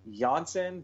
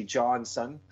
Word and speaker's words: Janssen: This is one where he hits they say Janssen, but I just see Johnson. – Yeah. – That's me being American. – That's the Swedish pronunciation. It's like Jakob and Jacob.